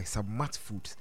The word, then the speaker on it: Sabmart: But before I give you the latest track in town, Plus Conversation was brought to you by Submart Foods. Submart is spelled Sabmart.